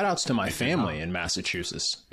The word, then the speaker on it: Massachusetts: Massachusetts. I said that wrong. Massachusetts. Yeah. – Massachusetts.